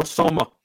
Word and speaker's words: Soma: But the actual title is Shokugeki no Soma. You won't know how to write that down, so I will Discord hold on. – You. – Shokugeki no Homa? – No Soma.